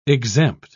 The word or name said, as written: exempt